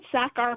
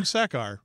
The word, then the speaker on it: Sachar: Is that how that's pronounced? – I've always said Sachar, but I don't know. – No, you know what you're doing. Sachar. Okay. No, you're right.